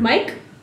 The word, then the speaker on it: MYQ: Mike, maybe? – Oh, Mike and Mike. Okay. So it says MYQ Kaplan, and then right afterwards in parentheses, pronounced Mike Kaplan.